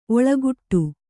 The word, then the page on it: ಓಲೆಗೊಡೆ: Kannada